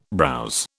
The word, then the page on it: browse: British English